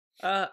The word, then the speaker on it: Catan: Is that the correct pronunciation? – Yes, Catan. – Because I've always heard Catan. – For everyone out there, it is Catan.